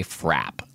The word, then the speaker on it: frappe: And in parts of the Northeast, instead of milkshakes, they say frap. – Oh, interesting. – Which I think is spelled F-R-A-P-P-E usually, but never pronounced frappe.